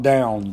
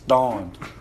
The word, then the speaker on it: Dawn: Not down, it's Dawn. They said the A was silent.